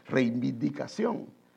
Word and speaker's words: reivindicación: Puede decir la primera palabra reivi y luego indicación. Reivi y luego indicación. Y ahí le va a ser fácil, reivindicación.